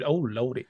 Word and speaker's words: Elordi: To be completely fair, too, I have to say that this film is very much just about Oscar Isaacs and Jacob Elordi. Like, so really, they're just additional people. – It's pronounced Jacob. Oh, Lordy.